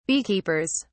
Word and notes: beekeepers: British English

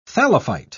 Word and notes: thallophyte: British English

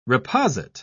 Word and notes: reposit: British English

